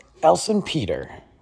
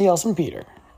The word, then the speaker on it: Elsenpeter: Jessie Elsenpeter. So you say it like, you know, some people, I'm pretty sure it is one word, by the way. It's not like Jessie Elson hyphenated Peter or something like that.